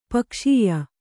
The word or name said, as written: ಪಗೆಸುಯ್